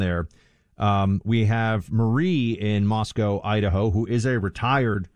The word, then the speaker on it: Moscow: We've also been told by people from the region that we're supposed to pronounce this Moscow. So we have learned, not like the way Americans pronounce Moscow, the city in Russia.